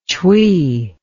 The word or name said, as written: tshi